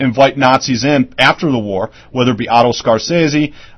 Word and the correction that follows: Skorzeny: and I think I pronounced that wrong. It's Otto Skorzeny, Skorzeny,